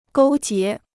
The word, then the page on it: 勾结: gōu jié